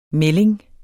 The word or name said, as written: MELDINGERNES